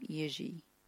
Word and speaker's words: Jiří: And I think this was back in 2010 or 11, and I believe from that he pronounced his name Jiří.